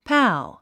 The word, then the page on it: pal: pǽl; パァゥ